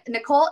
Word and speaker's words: Nicole: Oh, yeah, Nicole, N-I-C-O-L-E, Gebhardt, G-E-B-H-A-R-D-T.